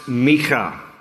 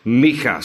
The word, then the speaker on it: Micah: His name is actually pronounced Micha. Micha. So one of our worship pastors is Micha Reeser, and you are free to call him Micha from now on.